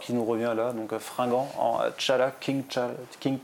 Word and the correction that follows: T'Challa: Alors moi je vais dire alors que c'était T'Challa, mm.